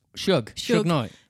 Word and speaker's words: Suge Knight: Suge Knight.